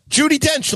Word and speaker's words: Judi Dench: It's more dignified than Duty Dench or whatever. – Thank you. I think that be careful of the pronunciation there. – Judi Dench, like it's that's not like you called her Judi Dench.